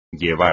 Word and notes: llevar: Spanish

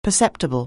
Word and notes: perceptible: anglais

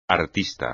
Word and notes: artista: espagnol

